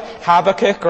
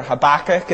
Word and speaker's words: Habakkuk: Or what about Habakkuk, or Habakkuk, as Americans say?